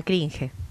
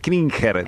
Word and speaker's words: cringe: Da cringe. – Claro, da cringe. Eso lo tenés que aprender. – Exacto, no, sí. La pronuncio siempre mal. Cringer, digo, se ríen los chicos.